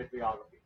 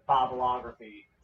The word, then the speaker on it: bibliography: The first time I ever, they put me in the advanced class, I went to the back and looked at the bibliography. I pronounced it Bibleography for some crazy reason, and somebody went, no, that's not how you pronounce it.